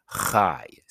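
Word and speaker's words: Chai (חי): In Hebrew, it's pronounced Chai, C-H-A-I.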